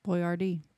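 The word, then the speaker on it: Boiardi: It's B O I A R D I. – Boyardee. – Yeah.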